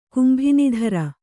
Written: ಕುಂಭಿನಿಧರ

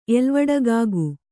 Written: ಎಲ್ವಡಗಾಗು

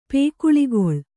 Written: ಪೇಕುಳಿಗೊಳ್

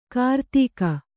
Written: ಕಿರುದೇಗು

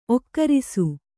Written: ಒಕ್ಕರಿಸು

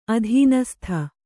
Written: ಅಧೀನಸ್ಥೆ

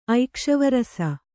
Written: ಐಕ್ಷವರಸ

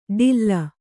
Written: ಡಿಲ್ಲ

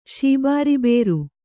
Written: ಶೀಬಾರಿಬೇರು

